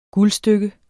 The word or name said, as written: guldstykke